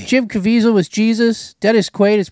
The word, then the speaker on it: Caviezel: Caviezel.